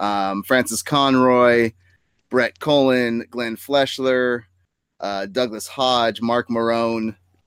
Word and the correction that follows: Maron: God, I love Mark that Mark Maron? Maron's casting Maron. Yeah, Maron. Okay. I love that Mark. Hey, at least I can pronounce it after.